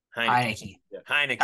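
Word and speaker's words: Heinicke: Henneke. Am I saying that right? – Heineke. Kind of like the beer.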